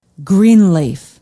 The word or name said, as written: greenleaf